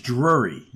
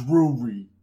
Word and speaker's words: Drury: most likely Drury, and I'm probably butchering his name, but... – It's Drury. Drury. – That's my North Jersey accent for you. – It's Drury.